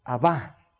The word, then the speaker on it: Abba: En la época de Jesús, esto está en arameo y se pronuncia Abba, no Abba como, mm, sí, como, ¿qué, se llaman? Sí, claro, pero el, género... Mm, mm, legumbres, legumbres, oh, legumbres. Gracias. No como la legumbre, no es aba es aba ¿Sí?